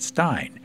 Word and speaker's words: Stijn: That's why he has a name that is spelled Stijn, but you pronounce it Stein.